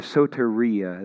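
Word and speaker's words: soteria (σωτηρία): The Greek word that is used there is soteria. That's spelled S-O-T-E-R-I-A. If you want to write that down, I probably should have put that in the notes. I'll spell that for those of you in the back. S-O-T-E-R-I-A, soteria.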